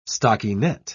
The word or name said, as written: stockinette